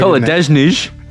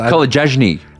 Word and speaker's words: Kolodziejczyk: Kolodziejczyk. – Isn't that how you say his name? – No. You were so confident, and then it's just a hard name. – I just thought... – Kolodziejczyk.